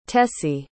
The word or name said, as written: tessie